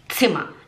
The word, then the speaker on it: Zimmer: Tipo, em vez de falar zima, a gente fala zima, porque Zima se escreve com Z, mas no alemão não é Zima, é tz, tz, tz, Zima, Zima. Agora tem dois M's, não é Zima, é Zima, Zima.